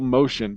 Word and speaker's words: Moschen: Yeah, Michael Motion, but it's MOSCHEN. So, I mean, it's spelled different.